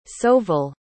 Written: sauville

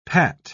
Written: pat

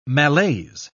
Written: malaise

